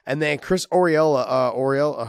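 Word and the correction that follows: Arreola: How do you say his last name? – Ariola.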